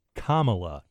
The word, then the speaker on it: Kamala: It is Kamala.